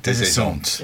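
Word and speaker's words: Des Esseintes: How's my pronunciation? – Desessent. – Yeah. Thank you. Not Des, as in Desessent.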